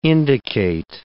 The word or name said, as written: indicate